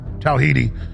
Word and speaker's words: Tawahidi: I always pronounce it wrong. How do you pronounce it? I've seen this guy for a decade. Tawahidi. Imam Tawahidi on France, the West, and the Islamic extremism.